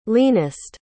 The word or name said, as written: leanest